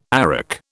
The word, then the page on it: arak: British English